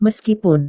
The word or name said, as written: meskipun